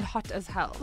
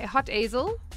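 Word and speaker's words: Hotazel: Google Maps can't find hot as hell. – Well, it's spelled differently. It's like hot as hell. – Yeah, like a Z, not an H.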